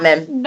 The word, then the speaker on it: Knopman: Not, Knopman. – Knopman. Okay.